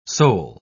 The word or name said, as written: sole